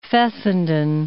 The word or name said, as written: Fessenden